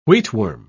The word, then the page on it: wheatworm: British English